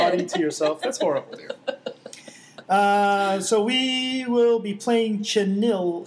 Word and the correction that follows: Chanel: How do you say that word? Chenille? – Chanel.